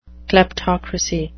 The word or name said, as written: kleptocracy